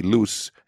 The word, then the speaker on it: Luz: One is L-U-Z, Loose, in Spanish.